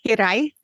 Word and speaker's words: hiraeth: It's called hiraeth. Hiraeth is how you say it. It's a Welsh word.